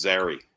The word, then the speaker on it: Zary: That is Zary. Rhymes with Larry.